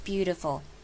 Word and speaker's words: beautiful: Because the most beautiful，好， most 一样，把什么省略，把尾音 T 给省略，只要发 most 就好， most beautiful，这样就不要念成 most beautiful，这样太 K 了。 OK， beautiful 要念得好听，其实这个字我们讲过很多次了，在 TI 身上， TI 把它念成 DI， beautiful，这样就会是好听的，不要念成 beautiful，也不要念 beautiful，念 beautiful，得得得。